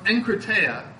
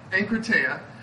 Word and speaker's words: enkrateia: So the word self-control is the word, it's pronounced enkrateia, enkrateia, and it actually means strength or power within.